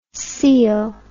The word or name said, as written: coel